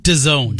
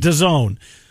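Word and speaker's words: DAZN: How do you say? Dazen? Dazon? – Dazone. – Dazone.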